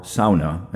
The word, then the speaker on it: sauna: The sauna, or sauna, as it's pronounced in Finland, has been around in some form for centuries.